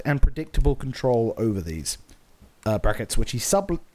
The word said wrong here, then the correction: subtly: I didn't read the word. Well, it was the right word, but I pronounced the B in subtly. Like a bad man. Which he subtly recognizes as creations of his own.